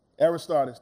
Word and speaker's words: Aristarchus: Aristarchus, thank you.